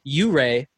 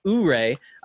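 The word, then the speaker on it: Ouray: My username is pronounced Hip Hip U-Ray, not Ooray.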